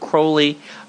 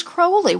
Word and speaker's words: Crowley: This is an esoteric school of thought founded by Aleister Crowley in 1939. – And it is Crowley. We've been taught this by our friends at the OTO. Crowley, which rhymes with holy, not Fowley.